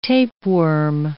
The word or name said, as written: tapeworm